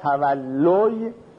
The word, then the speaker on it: تولی: در تولا باب تفعل، تولا یتولا تولی که در تلفظ میشه تولی، تبرا و تولی شنیدی، این هم به معنای پشت کردنه، به معنای ولایت پیدا کردنه، یعنی رئیس شدن، و همینطور معنای دوست داشتن.